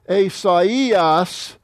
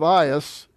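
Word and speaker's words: Asaias (Ἠσαΐας): Alpha iota is pronounced I, but the diaresis means you split them apart. You pronounce them individually. Asaias, instead of Ias, it's Aias. Asaias, that's how you say Isaiah in Greek.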